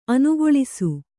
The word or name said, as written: ಅನುಕಲಿಸು